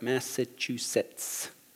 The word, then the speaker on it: Massachusetts: Did I say that right? Massachusetts. That's right. Cool.